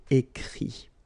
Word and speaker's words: écrit: Remember that even if you've got this final t, you don't pronounce it, so it goes like écrit, okay.